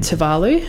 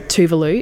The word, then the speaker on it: Tuvalu: Tom and Eileen were on their way back home to America after having served in the Peace Corps in Fiji and Tuvalu. Oh, sorry, Tuvalu is how it's pronounced.